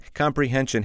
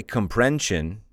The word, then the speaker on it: comprehension: Comprehension has four syllables. Comprehension. So that you don't say comprension.